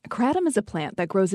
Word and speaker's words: kratom: This week, the Food and Drug Administration issued an advisory about kratom, also commonly pronounced kratom, which is an herbal supplement that's used to treat pain, anxiety, depression, and addiction.